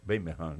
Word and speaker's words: Birmingham: Eles pronunciam Birmingham, né? O inglês.